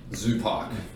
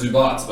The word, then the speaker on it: Zubac: Shout out to Laker legend in Ibiza, Zupac. Okay? – Zupac. It's a Zupac. Oh, no, his name is pronounced Zubac, but we call him Zupac.